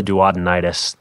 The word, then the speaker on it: duodenitis: I feel sluggish at times with my regular diet, and I have stomach-related problems such as gastritis and duodenitis? Is that how you pronounce it? – Probably duodenitis. That's an inflammation of the duodenum, part of the small intestine.